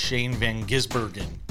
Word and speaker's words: Shane Van Gisbergen: Chris Buescher, your winner, beats out the road course specialist, Shane Van Gisbergen.